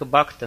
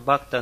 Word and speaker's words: бхакта: Мы думали, другой вариант только бхакта, но бхакта настолько трудно произносится русскими людьми, особенно потому, что они постоянно пытаются это «хэ» произнести. Бхакта. Бхакти. Бхактивиданта. Это «хэ» застревает все время в горле.